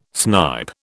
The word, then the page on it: snipe: British English